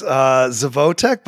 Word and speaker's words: Zevotek: It's close, Zevotek, but I really appreciate it, Tony.